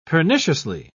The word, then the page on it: perniciously: British English